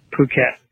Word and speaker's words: Phuket: Not pronounced like it's spelled, but Phuket.